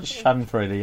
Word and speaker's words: Schadenfreude: Schadenfreude? Is that how it's pronounced? I forgot. Is it Schadenfreude? – Schadenfreude, yeah. – Okay. – That's it.